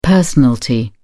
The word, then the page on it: personalty: British English